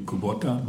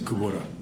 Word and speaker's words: Kubota: Well, in Japanese it would be Kubota, but in Hawaii you don't say it like that. You say Kubota.